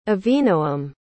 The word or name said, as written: avinoam